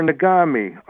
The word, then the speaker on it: Nagami: Okay, so, Bob, we had a caller call in and say the variety you want that will taste good is Nagami, and I think that's spelled N-A-G-A-M-I. – Oh, M-A-G. – N as in Nancy. N-A-G, A-M-I.